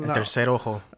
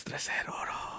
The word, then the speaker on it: tercer ojo: El tercer ojo. – That's tercer ojo. I completely botched that pronunciation.